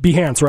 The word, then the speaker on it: Behance: Was it Behance, right? – Yeah.